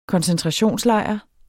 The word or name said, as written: koncentrationslejr